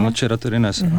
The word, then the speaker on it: Nocera Terinese: Snímek byl natočený během starobylého flagelantského rituálu, který se odehrává během svatého týdne v Kalábrii, v městečku, teď nevím, jestli to vyslovuji správně, Nocera Terinese? – Ano, Nocera Terinese, ano.